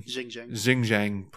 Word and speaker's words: Xinjiang: Xinjiang province of China.